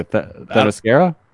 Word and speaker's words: Themyscira: Themyscira? – There it is.